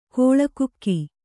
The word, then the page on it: ಕೋಳಕುಕ್ಕಿ: kōḷa kukki